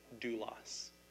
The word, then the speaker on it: doulos: That's how you pronounce it, David, doulos.